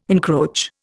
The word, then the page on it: encroach: Indian